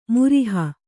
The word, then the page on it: ಮುರಿಹ: muriha